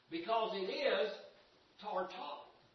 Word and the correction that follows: Tartarus: See, I can't even get it out. I've had Tartars for so long that it is Tartarus is its proper pronunciation.